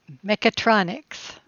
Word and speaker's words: mechatronics: It's a little out of order, but so you had this degree in mechatronica, and did I say that right? Mechatronica? – Mechatronics. – Mechatronics. Okay, I'm sorry. Mechatronics, not mechatronica.